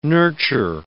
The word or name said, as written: nurture